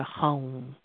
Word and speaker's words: Hum: And we're going to chant to ourselves just for a moment the mantra, the Bij mantra, Hum. Hum. You know, it's pronounced like hung, but with a kind of a nasal at the end.